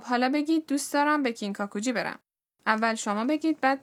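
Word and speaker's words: کینکاکوجی (金閣寺): کینکاکوجی.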